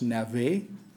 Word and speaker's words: Navez: This is François-Joseph Navez, sometimes pronounced Navet.